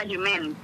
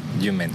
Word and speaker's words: Jument: En tu blog, en El Faro de la Jument, o Jument, porque no sé exactamente pronunciarlo. – Es El Faro de la Jument, es un faro francés que hay en la isla de Huesca y es el Jument.